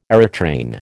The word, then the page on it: aerotrain: American English